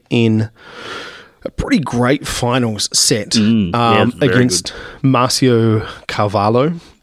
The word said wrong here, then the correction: Carvalho: Carvalho, sorry. – You got to miss wrong syllable there, man. – Yeah, wrong emphasis.